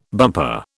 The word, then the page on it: bumper: British English